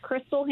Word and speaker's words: Chrystal: It's Crystal Hammer, and you just have to search it.